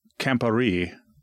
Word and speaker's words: Campari: It's pronounced Campari.